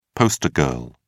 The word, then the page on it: poster girl: British English